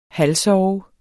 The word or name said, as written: halvsove